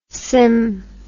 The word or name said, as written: sym